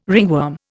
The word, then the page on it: ringworm: Indian